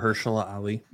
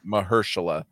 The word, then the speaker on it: Mahershala Ali: Do you think that that's a big enough, like, no one saw Blade coming and how do you say his name? I always fuck it up. – Mahershala Ali. – Mahershala.